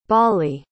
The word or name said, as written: balli